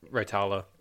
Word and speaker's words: Raitala: Raitala.